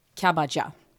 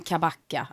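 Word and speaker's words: Kabaca: Det är ett turkiskt namn. Och c uttalas j. Kabaja. Mm. Mm. Men, men, mm. – det är lätt att säga liksom om jag ska boka bord någonstans eller så här, ja men kabacka, att c uttalas som ett k, b, a, c, a stavar man ju.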